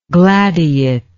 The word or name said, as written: gladiate